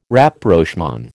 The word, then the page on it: Rapprochement: American English